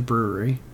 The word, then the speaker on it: Bruery: His last name is R-U-E. So the brewery is spelled B-R-U-E-R-Y. Very clever. He put his last name right in the middle. Little play on words because it still is pronounced the brewery, but has his last name in it.